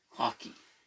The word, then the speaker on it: Haki: In One Piece, they have Haki. – Haki? – Haki. – Hockey. – H-A-K-I. – Hockey. Hacky? – Hockey. It's pronounced hockey.